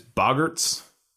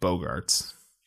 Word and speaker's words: Boggarts: as I pronounced it when I read the book, Bogarts. – Yeah. – When I read it, they were Bogarts, and then I saw the movie and they're like Boggarts, and I'm like, all right.